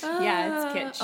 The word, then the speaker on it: kitsch: Yes, and it's pronounced kitsch, guys. – Yeah. – Remember that. – We don't make any egg dishes, quiches at kitsch, but anyway, it's kitsch.